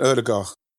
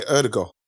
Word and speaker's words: Ødegaard: Is that how you say Ødegaard? It's Ødegaard, isn't it? I think people are going to probably, in England, they're going to say Ødegaard because like we're so lazy in the pronunciation, but it's Ødegaard.